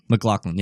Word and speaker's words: MacLachlan: MacLachlan, yeah.